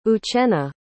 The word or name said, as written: uchenna